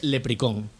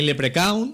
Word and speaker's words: leprechaun: Se pronuncia sencillamente leprecón. – Leprecón. – Sí. – Vale. – Ni leprecaun, ni leprechaun, evidentemente no. – Leprecón. – Leprecón. – Como replicón, pero leprecón.